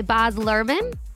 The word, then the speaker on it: Baz Luhrmann: The movie is directed by Baz Luhrmann.